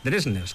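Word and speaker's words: Drizners: pero al parecer en noruego, pues esa DV se pronuncia como una DRJ, Dresners, que es como él mismo lo dice,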